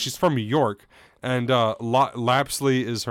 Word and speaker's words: Lapsley: Other than, okay, so technically her name is Lapsley, not Lopsley. I've been pronouncing it with the accent.